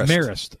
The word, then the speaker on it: Marist: Marist.